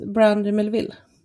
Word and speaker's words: Brandy Melville: Brandy Melvin, eller Mel... – Ja, precis. Brandy Melville.